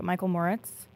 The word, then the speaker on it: Michael Moritz: So from what I know, and correct me if I'm pronouncing his name wrong, but Michael Moritz, is that correct? – Yeah, you got it.